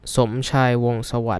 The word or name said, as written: Somchai Wongsawat